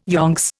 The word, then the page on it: yonks: Indian